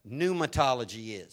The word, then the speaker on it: pneumatology: I want you to understand what pneumatology is. Pneuma is spelled with a P. P-N-E-U-M-A-T-O-L-O-G-Y.